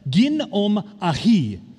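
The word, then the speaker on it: gin om ahi (γίνομαι): The Greek word um, for became is pronounced this way gin om ahi. That was very Japanese, um, but it's, it's what I got.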